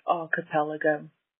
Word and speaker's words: archipelago: Just listen to today's episodes and I feel as the earth scientist weirdo, it's my duty to call in and say it's pronounced archipelago.